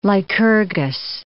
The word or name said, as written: Lycurgus